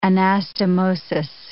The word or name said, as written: anastomosis